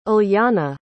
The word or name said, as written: ulyana